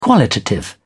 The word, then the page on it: qualitative: British English